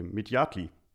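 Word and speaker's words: Midyatli: Ähm, Serpil Mityatil, nee, Mityatli.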